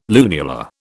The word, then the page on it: lunula: British English